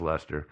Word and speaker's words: Leicester: But it's pronounced Leicester.